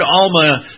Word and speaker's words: Almah: A-L-M-A-H. That's not the Hebrew word, but that's how we would pronounce it in English.